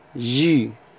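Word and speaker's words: jus: الكلمه اللي بعدها جي، عصير يعني، ليس لا تنطق.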